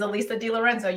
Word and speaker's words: Alisa DiLorenzo: It is Alisa DiLorenzo.